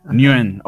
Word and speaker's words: Nguyen: creo que se pronuncia Nguyen, si, ah, si no estoy mal. Creo que se pronuncia. – Nguyen, ok. Dave Nguyen, para pronunciarlo bien.